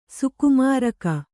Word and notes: ಸುಕುಮಾರಕ: Kannada; sukumāraka

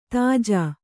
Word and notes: ತೇಜಿ: tēji

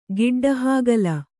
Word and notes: ಗಡ್ಡೆಹಾಗಲ: gaḍḍehāgala

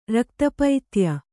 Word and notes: ರಕ್ತಪೈತ್ಯ: Kannada

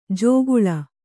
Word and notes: ಜೋಗುಳ: jōguḷa

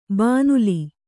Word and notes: ಭೂನೆಲ್ಲಿ: bhū nelli

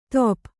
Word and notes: ತೊಪ್: top

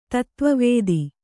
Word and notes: ತತ್ವವೇತ್ತ: tatva vētta

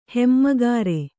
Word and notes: ಹೆಮ್ಮೆಗಾರ: hemmegāra

